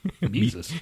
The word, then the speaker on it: Mises: Mises. It rhymes with Jesus.